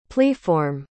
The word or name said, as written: pliform